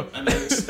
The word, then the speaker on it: Annaixe: Let her eye silent. – The eye is silent. – It's really Annaixe. – Oh. – Annaixe. Like, want a refund, dog.